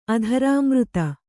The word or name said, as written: ಅಧರಾಮೃತ